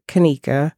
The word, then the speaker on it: Kneikha: First of all, my name is Kanika, and it's spelled K-N-E-I-K-H-A Shanahan, S-H-A-N-A-H-A-N.